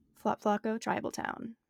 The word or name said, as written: Thlopthlocco